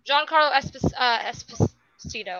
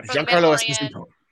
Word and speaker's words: Giancarlo Esposito: Giancarlo Esposito, I think I said his name right this time. – Yeah, Giancarlo Esposito.